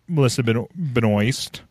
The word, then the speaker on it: Melissa Benoist: And when you look at Melissa Benoist, because that's how you say it.